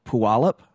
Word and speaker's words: Puyallup: I'm going to say this wrong, Puyallup, Washington. – You did really good. – Puyallup.